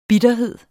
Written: bitterhed

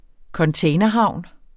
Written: containerhavn

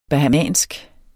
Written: bahamansk

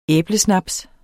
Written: æblesnaps

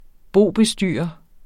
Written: BOBESTYRERE

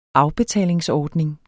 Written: afbetalingsordning

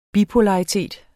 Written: bipolaritet

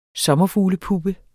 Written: sommerfuglepuppe

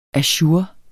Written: ajour

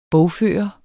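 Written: BOGFØRTE

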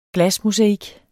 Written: glasmosaik

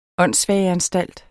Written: åndssvageanstalt

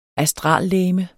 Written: astrallegeme

